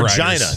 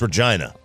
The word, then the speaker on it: Regina: Regina. It's Regina. – Sorry, what was that? – Oh, man. – It's pronounced what? – Regina.